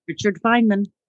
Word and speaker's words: Richard Feynman: So she taps into wisdom of all the ages from great minds like Plato, Jung, Marcus Aurelius, Helen Keller, William James, Richard Feynman. I probably said that wrong. – Richard Feynman.